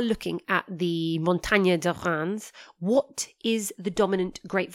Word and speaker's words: Reims: And when I say Reims, it's spelled R E I M S.